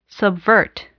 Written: subvert

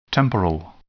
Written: temporal

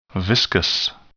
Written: viscous

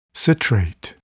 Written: citrate

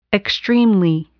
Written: extremely

